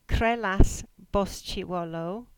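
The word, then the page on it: Bosullow Trehyllys: Cornish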